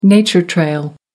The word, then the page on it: nature trail: American English